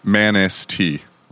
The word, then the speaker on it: Manistee: It's Manistee, like Manistee. – Manistee. – Yeah. – Did I say it right? – Yep. – Manistee. Okay. Almost rhymes with manatee.